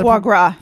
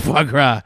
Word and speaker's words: foie gras: Shigra, which sounds like a fattened liver, like faux gras or something like that. How do you pronounce that? Did I pronounce that? – Foie gras. – Foie gras.